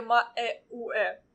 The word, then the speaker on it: Маэуэ: Ну, он скорее ма-э-у-э. – Ну, ма-э-у-э. – а а ы Ну, какая разница, как это по-русски пишется? Лучше произносить имя как в оригинале, как по-японски звучит.